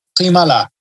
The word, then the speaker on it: Klimala: Okay, so pardon me for saying his name wrong if I do say it, but Patrick Klimala. Did I say it right? – Yeah, you said it right.